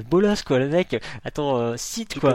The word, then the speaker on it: Scythe: Oui, parce qu'il y en a qui disent Scythe aussi, enfin il y a un peu toutes les prononciations, mais je crois que la vraie prononciation c'est Scythe.